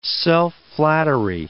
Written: self-flattery

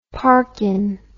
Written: parkin

